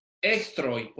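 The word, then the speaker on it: extroi (ἐχθροί): Se pronuncia extroi, porque está en plural. Esta terminación dice que es plural, enemigos. Si no, diría extros.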